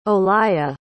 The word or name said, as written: olalla